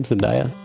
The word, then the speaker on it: Zendaya: And then he runs into Zendaya out in the, is that how you say her name? Zendaya? – Yeah. You got it.